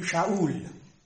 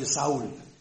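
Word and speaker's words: Sha'ul (שאול): Das hebräische Wort Sha'al, das klingt ganz ähnlich wie Sha'ul. Wir sagen heute Saul, aber hebräisch ausgesprochen Sha'ul, klingt wie Sha'al.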